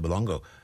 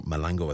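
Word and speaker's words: Molango: The PFA chief executive, Maheta Malango, and apologies for that pronunciation, it's more Malango, I think, backed Manchester United defender Rafael Varane, who branded the new rules dangerous, damaging and senseless.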